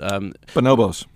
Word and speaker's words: Bonobos: They made an investment recently in Bonobos. I believe that's how you pronounce it. – Bonobos.